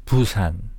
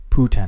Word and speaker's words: Busan: It's Busan. – Busan. How do you spell that? – B-U-S-A-N. – S-A-N. Busan.